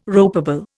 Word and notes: ropeable: Indian

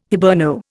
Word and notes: Hiberno: Indian

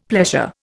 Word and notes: pleasure: Indian

